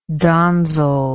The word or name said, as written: donzel